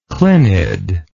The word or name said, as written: clinid